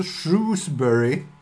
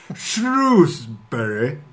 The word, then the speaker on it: Shrewsbury: Vi möter alltså Shrewsbury. – Jag tycker det var ett fantastiskt brittiskt uttal. Får höra igen. – Shrewsbury.